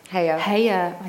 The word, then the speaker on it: Haya: She's 21 and the director of a fashion business called Urban H-A-Y-A. Oh, have I said that right? – Heya.